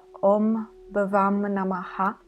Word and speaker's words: Om Bhavam Namaha: Durante il settimo episodio abbiamo parlato di questo mantra che si pronuncia Om Bhavam Namaha,